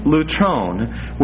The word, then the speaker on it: lutron: Now, there's a Greek term, it's the term lutron, which is spelled L-U-T-R-O-N, lutron, which is translated in the Bible as ransom.